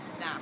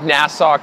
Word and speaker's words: Nassau: I would like to confirm how to pronounce Nassau. – N A S S A U. A U. It's Nassau. Na- not Nassau. I'm pretty. I'm pretty sure. Nassau. It's not Nassau.